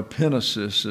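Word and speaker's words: epinesis (ἐπίγνωσις): He has cycled it through pistis, epinosis, or epinesis is the way it's pronounced in the Greek. I've been criticized because I have put a great deal of effort into pronouncing Greek words as they are written, not as we typically say them. And you can see the emphasis is on the iota.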